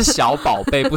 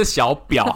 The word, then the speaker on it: 小宝贝: 你刚才发音要准一点，是小宝贝不是小表好不好。